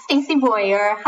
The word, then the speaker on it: Stacie Boyar: Yes, Stacie Boyar.